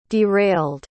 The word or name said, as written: derailed